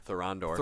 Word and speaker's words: Thorondor: Thorondor. – Thorondor.